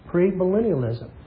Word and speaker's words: premillennialism: Premillennialism.